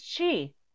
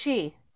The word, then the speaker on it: Qi: QI is Chi. – Is what? – QI is pronounced Chi.